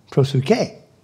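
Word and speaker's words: proseuchē: Prosuke. That's how, when I hit the little button, it pronounced it.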